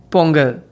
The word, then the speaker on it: Pongal: And Pongal.